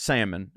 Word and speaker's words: salmon: And I just go, it's salmon. And he goes, what? And I go, salmon, not salmon. Like, you don't pronounce the L.